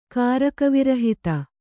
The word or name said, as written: ಕಾರಕವಿರಹಿತ